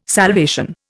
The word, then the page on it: salvation: Indian